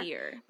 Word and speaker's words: Sear: I want to say it's spelled S-E-A-R, like Seer. – But it's like mm-hmm. a seer.